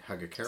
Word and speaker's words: Hagakure: The Hagakure, I'm not sure if I'm pronouncing that right. Hagakure? – Sounds right. – That's about right.